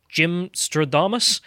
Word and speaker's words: Gymnastradamus: And today we're going to be talking about the, how do you say this, Gymnastradamus? – Yes. – There we go. God, at least I got it right.